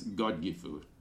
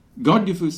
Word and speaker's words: Godgifu: Oh, and by the way, I'll say I use the Old English uh, spelling and pronunciation of Godiva, so uh, it's Godgifu. – Godgifu slumped to her knees.